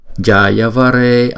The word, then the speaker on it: Jayavare: Om Vimale Om Vimale Jayavare Amrite. Cái chữ J này này thì chúng ta phải đọc là J J Jayavare Amrite.